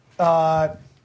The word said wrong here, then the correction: N'Keal: Oh, Nikhil Harry. – Nikhil? – Yeah, I'm sorry, Nikhil. I'm sorry, Nikhil. – Yeah. – Nikhil in this case is spelled N apostrophe K-E-A-L.